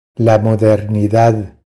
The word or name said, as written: La modernidad